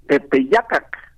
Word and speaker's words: Tepeyacac: Tepeyacac, dicho en agua. En español decimos Tepeyac lo castellanizamos la pronunciación.